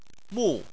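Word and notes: mu: tone 4

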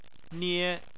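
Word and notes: nie: tone 1

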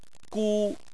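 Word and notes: gu1: tone 1

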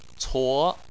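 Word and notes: cuo: tone 2

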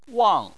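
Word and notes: wang: tone 4

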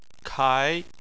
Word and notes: kai2: chinese

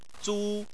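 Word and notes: zu: tone 1

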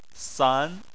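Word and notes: san: tone 2

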